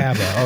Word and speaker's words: Kaaba: You can see there's sort of a, am I pronouncing that wrong? – Is this the Kaaba? – It's fine. – Kaaba. Okay. I'm going to call it the Kaaba now.